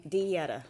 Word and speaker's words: Donyetta: Donyetta.